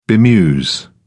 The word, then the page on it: bemuse: British English